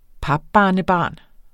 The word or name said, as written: papbarnebarn